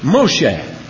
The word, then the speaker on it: Moshe: This is pronounced, you read it from right to left, Hebrew. M-O-S-H-E-H. Moshe. Moshe.